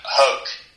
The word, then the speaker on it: Hoke: Yeah, there's a wonderful woman in America by the name of Catherine, I believe it's pronounced Hoke, H-O-K-E.